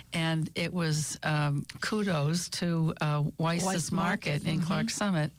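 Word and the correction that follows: Weis: I'm sorry, Wise, you're right. That's the way they pronounce it.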